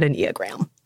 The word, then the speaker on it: Enneagram: And brief disclaimer, we came across many pronunciations of the word Enneagram, but we're pronouncing it Enneagram.